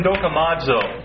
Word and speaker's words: dokimazo: And he uses the Greek term dokimazo. That's spelled D-O-K-I-M-A-Z, long O. Dokimazo.